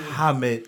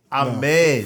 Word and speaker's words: Ahmed: No, he added H- first of all, the H is silent. – No, it's Ahmed. There's no H sound, period.